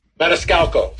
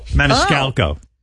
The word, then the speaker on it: Maniscalco: Maniscalco. How do you say his name? – Maniscalco. – Maniscalco.